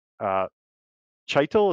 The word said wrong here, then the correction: Chytil: is that how you say his name? – Cheadle? Philip Cheadle. Yep, Cheadle. Yep. – Cheadle. – Yeah, I made that mistake too. You see it and it's like, oh, Chytil.